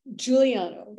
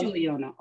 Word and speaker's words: Giuliano: Giuliano. – It is Giuliano.